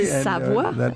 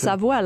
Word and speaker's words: Savoie: Savoie. It's pronounced Savoie, like the region.